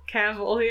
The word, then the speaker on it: Cavill: Is that wrong? Is it Cavill? – Cavill, yeah. – Is it Cavill? I like Cavill. – like it rhymes like with Cruella DeVille. Yeah.